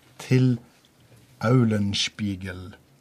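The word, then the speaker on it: Till Eulenspiegel: Eh, dette her uttrykket eh, har jeg nå eh, funnet ut at det skriver seg fra ei fortelling som heter 'Til Aulenspiegel'. Det, det, var korrekt uttale? – Det var bra.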